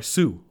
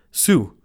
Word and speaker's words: Suh: Heard some people talking about the Rams going after Sa, or sorry, Suh. I always say his name wrong. Suh.